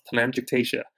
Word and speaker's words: telangiectasia: Telangiectasia.